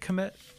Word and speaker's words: Kmet: Did I say that right? Is it Kmet? – Yep.